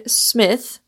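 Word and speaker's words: Psmith: His four novels featuring Smith, with a silent P, were scattered over several years.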